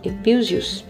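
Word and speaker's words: epísios (ἐπιούσιος): Esta palavra epísios, pronuncie epísios, não é usada em nenhum outro lugar no Novo Testamento.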